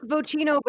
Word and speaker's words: Vocino: It's Vocino, but I honestly, either way, my name has been butchered so much.